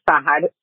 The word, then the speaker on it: Sahad: My name is Sahad, phonetically pronounced S-A-H-A-D.